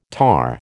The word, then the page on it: tahr: American English